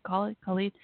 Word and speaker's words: Khalid: So featuring Khalid. I'm not saying his name right. Khalid.